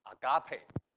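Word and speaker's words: Agape (ἀγάπη): Agape，请大家记住这个词，来读一遍，一起来，Agape，耶，Agape。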